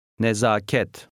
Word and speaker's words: Nezaket: Read 14, which has the longer A. The stress is on the last syllable. – Nezaket.